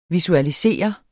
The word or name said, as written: visualisere